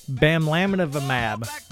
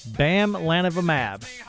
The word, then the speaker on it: bamlanivimab: Bamlanivimab. Bamlanivimab.